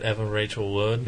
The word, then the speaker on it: Evan Rachel Wood: Evan Rachel Wood? – Yeah.